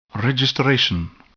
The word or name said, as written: registration